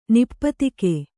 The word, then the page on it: ನಿಪಾತಕ: nipātaka